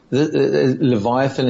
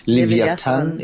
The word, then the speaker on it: Leviathan: Okay, so this is Leviathan is the way that it's kind of spelt in English. Leviathan is the way that it's pronounced in Hebrew.